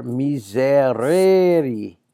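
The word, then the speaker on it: misereri: Miseror, miserere. Notice the long E, the sign of the second conjugation.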